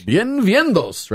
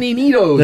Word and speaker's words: bienvenido: Bienvenidos, right? How do you say it? Say it the real way. You can say it loud. – I didn't say it right? – Well, I don't. – Yeah. I said, bienvenido. – Bienvenidos. – No, it doesn't have the S on it. – Bienvenidos. – There is no S in this one.